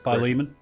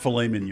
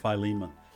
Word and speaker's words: Philemon: Philemon. – Philemon. So those are the... – Not Philemon, y'all. Philemon.